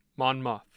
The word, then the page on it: monmouth: muth